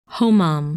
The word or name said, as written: Homam